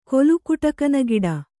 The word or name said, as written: ಕೊಲುಕುಟಕನಗಿಡ